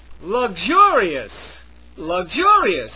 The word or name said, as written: luxurious